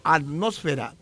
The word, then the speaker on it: atmósfera: De todas ellas, solo la palabra atmósfera es esdrújula. Las demás son graves y llevan el acento sobre la sílaba fe.